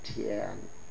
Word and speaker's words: Thien: In Korean it's called Sun, S-O-N, and in Vietnamese it's called Tien, T-H-I-E-N.